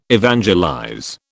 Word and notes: evangelize: British English

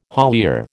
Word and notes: haulier: American English

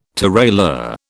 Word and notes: tirailleur: British English

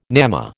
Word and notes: gnamma: American English

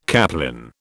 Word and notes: caplin: British English

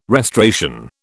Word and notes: restoration: British English

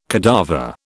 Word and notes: cadaver: British English

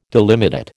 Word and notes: delimitate: American English

